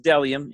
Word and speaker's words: Bdelliom: It's pronounced Delium, even though there is a B in the front. It is B-D-E-L-L-I-O-M.